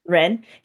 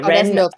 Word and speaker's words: Wren: And then we have I don't know if I'm saying this right. It starts W-R-E-N. That's how you spell his first name. – Ren. – Okay, Ren. Yeah, Ren T.